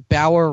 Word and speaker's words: Bauer: Because Bauer rhymes with hour, as in 24 hours.